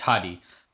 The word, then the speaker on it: Hadi: Hadi, it's Hadi.